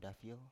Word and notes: dafio: Biak

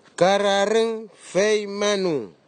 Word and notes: kararigfeimanu: Nafe; Tanna

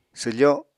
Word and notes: swfo: apatani

